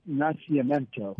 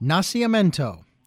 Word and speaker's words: Nacimiento: It's pronounced Nacimiento. – Nacimiento.